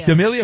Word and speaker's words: D'Amelio: Dixie D'Amelio. Is that, am I saying that right? Yeah, but it's not 25. Is that an actress? – D'Amelio.